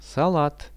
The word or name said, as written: salat